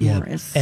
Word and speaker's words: Maurice: It's spelled Maurice, but I think they pronounced it Morris.